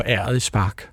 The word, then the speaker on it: spark: Altså, hvis man slår op i den store danske udtaleordbog fra 91, så vil man for eksempel falde over en distinkt udtale af ordet spark, som hedder spark. – Spark. – No. Med det her konsonantiske R før. – Altså, man trækker faktisk lidt på R'et i spark.